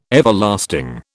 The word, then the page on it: Everlasting: British English